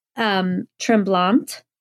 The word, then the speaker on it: Tremblant: Yeah, you can tremblant. Say it like that. Tremblant. It's in Quebec, Canada.